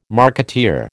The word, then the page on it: marketeer: American English